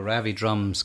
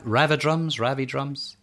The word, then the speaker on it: Ravid Rums: R-A-V-I... Oh, Ravid Rums is how you're pronounced. I'd assumed it was Ravi Drums, because drums seems to be a big part of the performance there.